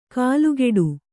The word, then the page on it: ಕಾಲುಕೆಡು: kālukeḍu